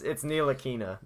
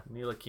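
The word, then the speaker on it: Ntilikina: Oh, it's not Nicotina. – It's like Nitalkina, something like that. – No, it's Nilakina. Nilakina.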